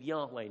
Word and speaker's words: Yahweh: Well, Yahweh is the probably more correct way to pronounce it.